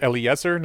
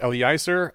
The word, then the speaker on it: Eliezer: It's pronounced Eliezer, not Eliezer.